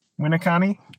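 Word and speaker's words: Winneconne: And then you can make fun of me for how I say like Winnicon. – Winnicani? – Oh, the E is pronounced?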